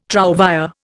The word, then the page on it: trouvÊre: Indian